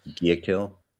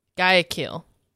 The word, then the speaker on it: Guayaquil: Isn't it just Guayaquil? – Guayaquil. Guayaquil, Ecuador. So he was born in Guayaquil, Ecuador. At the time of his birth, Judge's father was working for a not-for-profit organization in Guayaquil and other parts of Ecuador, which is why he was actually there, and that's why he was born there.